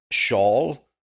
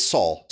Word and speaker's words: Saul: But, you know, I think they pronounce it Shaul or Saul. – Okay. – But it's Saul.